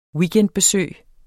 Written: weekendbesøg

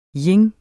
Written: yin

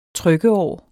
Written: trykkeår

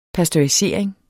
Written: pasteurisering

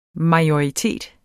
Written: MAJORITETS

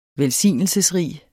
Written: velsignelsesrig

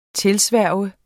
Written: TILSVOR

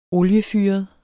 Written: oliefyret

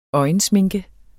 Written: øjensminke

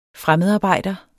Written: fremmedarbejder

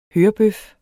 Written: hørebøf

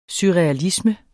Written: surrealisme

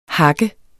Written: hakker

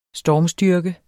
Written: stormstyrke

